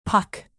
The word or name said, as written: puck